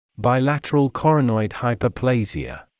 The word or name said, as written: bilateral coronoid hyperplasia